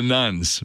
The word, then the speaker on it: nones: At first, when you hear it, it sounds like you're talking about Roman Catholic women. – Yeah, the nuns. – The nuns, but it's spelled N-O-N-E-S.